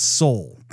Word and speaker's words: Seoul: We have not been pronouncing that name correctly. It's Seoul.